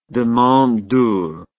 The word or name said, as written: demandeur